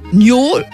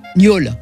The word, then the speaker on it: niole: L'orthographe n'est pas fixée, on trouve N-I-O-L-E ou bien N-I-O-2-L-E et moi je l'écrirai spontanément N-I-A-U-L-E pour que ça corresponde à la prononciation d'un O fermé, gnole et pas gnole.